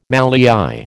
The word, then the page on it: mallei: American English